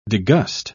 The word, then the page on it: degust: British English